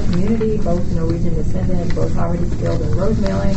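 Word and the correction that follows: rosemaling: Am I saying that right? – Rose mauling. – Rose mauling. – Yeah, but basically.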